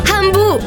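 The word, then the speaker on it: Hamu: Amu si scrive con l'H. – Amu, mi piaci tu.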